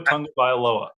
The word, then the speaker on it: Tagovailoa: Yeah, but they made it simple for us with just saying, hey, the first syllable is tongue. – Yeah. – Just say tongue, you're set. You're free. Tua Tagovailoa.